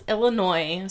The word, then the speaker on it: Illinois: Okay, yeah, it's Illinois. Sorry, I for sure learned how to say that right this time.